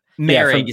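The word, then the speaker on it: Moehrig: You mentioned Morick. Is that how you say it? – Merrick is how you say his name.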